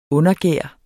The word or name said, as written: undergær